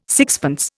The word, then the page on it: sixpence: Indian